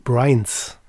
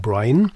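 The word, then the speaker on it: Bad Brians: Und jetzt kann ich schön überleiten, weil die folgende Band, die Bad Brains, auf die Betonung achten, nicht Brain, sondern Brian, die Berliner Band, die schon seit vielen Jahren existiert und wunderbarste Coverversionen überwiegend von alten Hardcore-Sachen macht, die haben ein neues Album.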